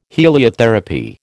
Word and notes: heliotherapy: American English

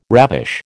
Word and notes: ravish: American English

